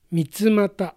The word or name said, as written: Mitsumata